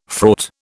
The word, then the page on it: fraught: British English